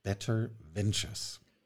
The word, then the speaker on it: Better Ventures: Clubs Better Ventures. Habe ich das korrekt ausgesprochen? – Äh, absolut richtig.